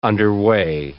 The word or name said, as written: underway